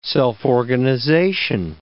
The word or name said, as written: self-organization